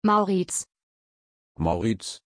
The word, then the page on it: Maurits: German